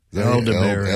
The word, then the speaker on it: elderberry: Edelberry, is that how you say it? elderberry. elderberry, yeah. Elderberry.